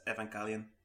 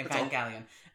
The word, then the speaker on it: Evangelion: But, outside of that, there's the NGE movie, the Neon Genesis Evangelion. Have I got that correct, Graham? – Neon Genesis Evangelion. – Evangelion, yeah. Apologies. – You just don't pronounce the G. – Okay, Evangelion.